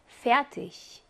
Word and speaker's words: fertig: Fertig.